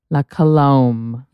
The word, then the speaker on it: La Colombe: La Colombe. – Colombe. Oh my God, everyone corrected me. It's La Colombe. – La Colombe. – La Colombe. Someone said, like comb. La Colombe.